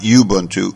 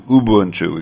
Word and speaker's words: Ubuntu: I'm told not Ubuntu, Ubuntu. We've got to give it the correct pronunciation.